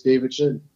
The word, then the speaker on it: David Chin: My Facebook is David Chin.